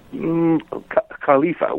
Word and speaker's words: Khalifa: Well, in this sense, the sultan slash caliph, obviously, caliph, what's the correct pronunciation? – Khalifa would be there. Khalifa, okay.